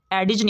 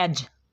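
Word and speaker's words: edge: एडिज नहीं पढ़ना, इसको पढ़ना है एज।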